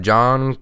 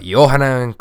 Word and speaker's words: Johann: And next up, we have Johan Kross. Not John. You can want to call him his American name and call him John. If you really don't want to pronounce anything, but Johann Kross.